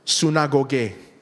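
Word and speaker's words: synagoge (συναγωγή): If you ever are curious on how to pronounce it in Greek, it's actually Greek. It's pronounced synagoge.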